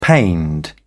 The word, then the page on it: pained: British English